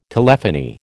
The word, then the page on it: telephony: American English